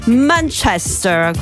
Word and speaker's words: Manchester: No, invece a un certo punto abbiamo scoperto tutti quanti che si dice Manchester, quindi quelli bravi dicono così e noi, anche se non siamo quelli bravi, ci vogliamo adeguare.